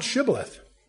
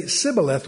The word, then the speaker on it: shibboleth: There's an S, but no SH sound in their articulation. So if you ask them, are you an Ephraimite? They say, no, not us. They'd say, pronounce shibboleth. And they couldn't do it. They would say sibboleth, which was a giveaway.